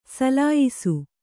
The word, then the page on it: ಸುಲಿಯಿಸು: suliyisu